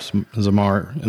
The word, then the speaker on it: Zamar: Zamar.